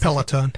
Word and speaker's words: Peloton: It's Peloton. – A, thanks. – Peloton. It's got an L in it.